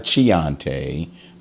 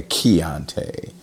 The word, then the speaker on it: Chianti: Not a Chianti, but a Chianti. It's spelled accordingly. C-H-A-N-T-I. Let's do it one more time because I'm an idiot. C-H-I-A-N-T-I.